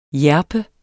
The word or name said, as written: hjerpe